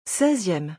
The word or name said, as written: 16eme